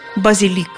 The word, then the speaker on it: базилик: Базилик или базилик? Не возмущайтесь, некоторые старые словари действительно приводили ударение «базилик», Проблема та же. Слово заимствовали из французского, где ударение на последнем слоге. Во французском – базилик – с ударением на последнем слоге. Но в латинском-то было «базиликус» с ударением на втором. Сейчас только «базилик».